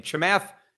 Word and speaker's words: Chamath: But he had Kemath Palepatia. I forget how to pronounce this guy's name. Chamath Palepatia.